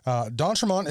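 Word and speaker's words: d'Entremont: So, you know, go on Instagram, follow Don Tremont Guitars.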